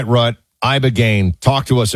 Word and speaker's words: Ibogaine: Ibogaine. Remember the name. Is that pronounced relatively properly, what you said? – Yes. – I don't want to get it wrong. Ibogaine, because it's so important, and experienced an 80 to 90% reduction in symptoms of depression and anxiety within 1 month.